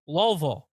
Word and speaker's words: Louisville: I think he's going to Louisville. – Louisville. Yeah, that's how they say. They don't like Louisville.